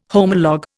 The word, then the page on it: homolog: Indian